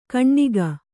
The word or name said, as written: ಕಣ್ಣಿಗ